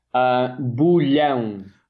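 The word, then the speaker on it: Bolhão: Help me pronounce that, please. – Okay, so I'm going to do it slowly because this is a toughie. Bulhão Market. – I wasn't Bulhão. Even close. I was not even close.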